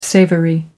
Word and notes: savoury: American English

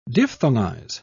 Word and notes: diphthongize: British English

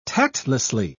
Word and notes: tactlessly: British English

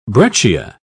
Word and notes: breccia: British English

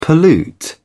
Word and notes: pollute: British English

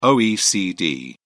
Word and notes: oecd: British English

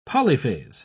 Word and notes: polyphase: British English

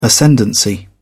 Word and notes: ascendancy: British English

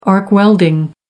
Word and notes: arc welding: American English

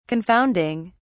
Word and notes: confounding: British English

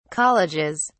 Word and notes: colleges: British English

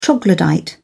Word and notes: troglodyte: British English

